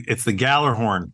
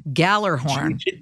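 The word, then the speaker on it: Gjallarhorn: It's the Gallerhorn. – Gallerhorn. – G-J-A-L-L.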